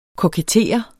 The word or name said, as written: kokettere